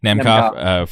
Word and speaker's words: Nemkov: Since we're on that side of the bracket, Vadim Nemkov, am I pronouncing it? Nemkov.